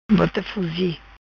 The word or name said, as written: Bota Fuzi